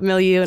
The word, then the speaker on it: milieu: Am I saying it right? Milieu? – Yes. – All right. Okay. – It's phonetic.